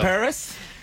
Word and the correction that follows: Alvise Pérez: Alvise Pérez, Laura, ¿para qué se han reunido más de 61.000 firmas en Argentina?